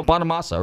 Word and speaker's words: Bonamassa: Hey, you're tuning into Nostalgic Radio and Cars, and let's play a little Joe Bonamassa.